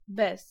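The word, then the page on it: bez: IPA: /bɛs/; Polish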